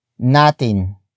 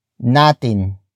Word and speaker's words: natin: The next word is natin. Natin. Natin. Natin is spelled as N-A-T-I-N.